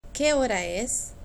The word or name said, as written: que hora es